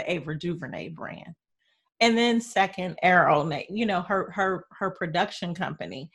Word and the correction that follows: Array: And I said it wrong, Array.